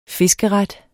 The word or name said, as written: fiskeret